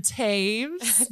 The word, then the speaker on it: Toews: Welcome to Doing Hard Things, Lauren Toews. Did I say it right? – Yeah. – Toews. Okay. So it's T-O-E-W-S, but it's pronounced Toews.